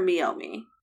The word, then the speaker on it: Meiomi: I have heard of Meiomi, and I have been wanting to try this. Is it Meiomi or Meiomi? – It's actually pronounced Meiomi.